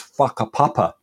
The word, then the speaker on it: whakapapa: In Maori culture, for example, there's the concept known as whakapapa, spelt with a WH, they pronounce WHF.